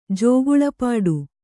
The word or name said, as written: ಜೋಗುಳಪಾಡು